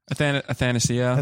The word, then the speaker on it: Athanasiou: Athanasiou.